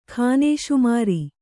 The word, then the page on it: ಖಾನೇಸುಮಾರಿ: khānēsumāri